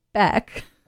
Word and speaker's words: Beck: It rhymes with deck. But it's Beck.